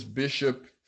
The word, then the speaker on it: Bixop: So, first off, my name is Bishop. For all that don't know, that's B-I-X-O-P. I spell it with the Portuguese. In Portuguese, the X has the S-H-O-P.